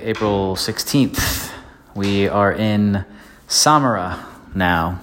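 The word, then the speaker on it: Sámara: S-A-M-A-R-A. Accent on the first A. We weren't sure because Nosara is right up the road. So was it Samara? Feel pretty confident at the moment that it's Samara.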